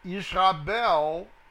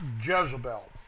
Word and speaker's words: Yizabel: Verse 7, and said Isabel, that's spelled Y-I-Z-A-B-E-L, not Jezebel.